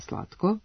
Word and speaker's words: slatko: jer se mešaju promene imenice prideva i priloga slatko, odnosno javljaju se njihove dve promene, imenička i pridevska. Različiti su im i akcenti i značenja, ali imenica slatko sa kratkouzlaznim akcentom na prvom slogu i dužinom na poslednjem može da se menja dvojako, pa je pravilno i slatka i slatkog, što je zabeleženo i u rečniku srpskog jezika Matice Srpske.